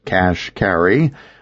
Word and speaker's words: Kashkari: Neil Kashkari, pronounced Cash Carry, started out as a low-level Goldman investment banker and now runs the bailout under the Government Office of Financial Stability.